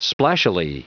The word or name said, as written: splashily